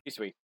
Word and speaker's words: Shisui: Shisui.